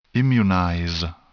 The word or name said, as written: immunize